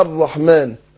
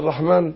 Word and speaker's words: الرحمن: الرحمن، تتلاقي بعد مكتوبه حاء وميم ونون على طول. احنا بنقول الرحمن، احنا بننطق الف بعد الميم، لكن هي مرسومه من غير الف.